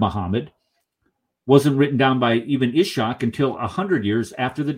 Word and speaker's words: Ibn Ishaq: Ibn Ishaq.